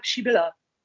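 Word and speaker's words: Przybilla: der hieß quasi so geschrieben äh, Prizibilla und zwar Pschibilla.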